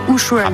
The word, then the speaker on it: Ushuaïa: Ushuaïa.